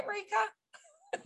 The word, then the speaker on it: Marieke: Did I say your name right, Marieke? – You did it perfectly.